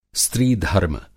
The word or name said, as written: स्त्रीधर्म